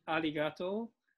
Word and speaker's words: arigato: We say basically arigato.